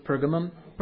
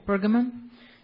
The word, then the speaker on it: Pergamum: Pergamum. – Pergamum.